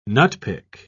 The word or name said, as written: nutpick